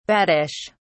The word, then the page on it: baddish: British English